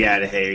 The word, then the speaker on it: Adahy: The correct pronunciation would be Adahi, but most people just say the Atahe.